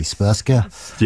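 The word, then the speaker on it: Srpska: I'm going to mispronounce this horribly. Spurska. – Yeah, not far off anyway.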